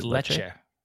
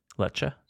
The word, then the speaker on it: Lecce: How do you know how to pronounce that? – Yes, it Lecce. – Lecce? – Yeah.